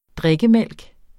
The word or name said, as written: drikkemælk